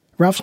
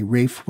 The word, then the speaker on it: Ralph: All of his sons were so named, and occasionally, at school or in the army, Ralph was called Williams, but that is not correct. Also, Ralph's name was pronounced R-A-F-E, and any other pronunciation used to infuriate him.